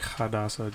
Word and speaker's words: Hadassah: Feels like Hadassah, just because Hadassah, you pronounced it right.